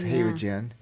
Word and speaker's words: Haogen: That I could be pronouncing it wrong. Mm-hmm. H-A-O-G-E-N or something like that. – Oh, like a halogen lamp? – Well, that would have an L.